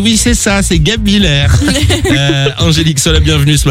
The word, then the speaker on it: Guebwiller: On va accueillir Angélique a 38 ans, elle est du côté de Guebwiller, c'est dans le 68, j'espère bien le prononcer. Salut Angélique. – Salut la team, comment vous allez? – Salut Angélique. Guebwiller, c'est ça qu'on dit? – Guebwiller, c'est ça.